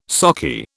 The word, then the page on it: sockeye: British English